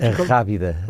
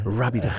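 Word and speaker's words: Arrábida: What can you just pronounce that mountains again? What Rábida. – Rábida. – Rábida Mountains, yes.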